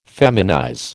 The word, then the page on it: feminize: American English